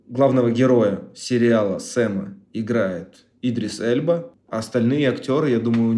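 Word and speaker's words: Idris Elba: Я, кстати, слышал, что правильно говорить Идрис Эльба.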